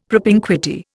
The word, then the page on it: propinquity: Indian